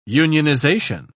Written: unionization

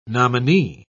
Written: nominee

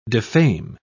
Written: defame

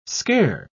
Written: scare

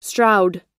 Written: stroud

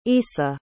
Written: issa

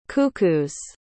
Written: cuckoo's

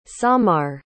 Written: samar

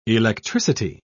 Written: electricity

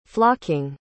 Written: flocking

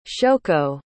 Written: shoko

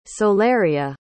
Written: solaria

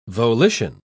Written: volition

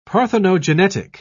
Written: parthenogenetic

